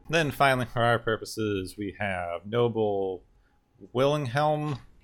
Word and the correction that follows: Willingham: I hope I'm pronouncing that right. I think it might be Wilhelm, but it's got an N in the middle of it, so I don't know if I'm supposed to pronounce that. – Willingham? – Willingham, there we go.